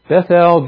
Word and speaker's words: Bethel: and there be none to quench it in Bethel. Bethel, Gilgal, and Beersheba were all places of religious significance for the people of Israel. Bethel, or Bethel as it's sometimes pronounced, literally means house of God.